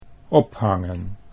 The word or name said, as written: ophangen